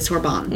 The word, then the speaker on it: Sorbonne: Right, like, her husband of 30 years had this opportunity to teach at the Sorbonne. – Mm-hmm. – Am I saying that right? – Yeah.